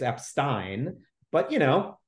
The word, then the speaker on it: Epstein: well, actually, it's pronounced Epstein, and, you know, draw attention to his Jewish name.